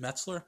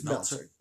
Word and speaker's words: Meltzer: I can read a Meltzer, right? Is it Metzler? – It's Meltzer. Yeah, thank you.